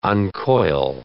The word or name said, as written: uncoil